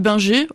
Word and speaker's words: Binger: Elles sont d'une part le fait de Louis-Gustave Binger. Alors on prononce plutôt son nom de façon très française.